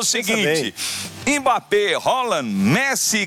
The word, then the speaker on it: Haaland: Ele falou: a pronúncia do meu nome é Haaland.